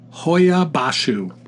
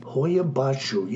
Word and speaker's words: Hoia Baciu: It is Hoyabashu. – Hoyabashu. Yeah, it's got a That's how you pronounce it, but it's H-O-I-A-B-A-C-I-U.